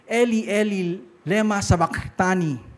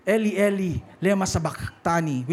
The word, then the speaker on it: Eli, Eli, lema sabachthani: Nung pinako si Jesus sa cross, sinabi niya, Eli, Eli, lema sabachthani. So kailangan may parang ganun na pronunciation. Eli, Eli, lema sabachthani, which means, my God, why have you forsaken me?